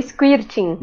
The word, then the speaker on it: squirting: É Squirt, Dri? Qual que é a pronúncia? – Não, vou, vou deixar pra sexóloga falar. – Pra tá? passar vergonha. – É o squirting. O squirting, exatamente.